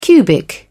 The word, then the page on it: cubic: British English